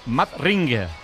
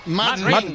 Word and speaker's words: Madring: Teóricamente en vez de Madrid, Madring. – Madring.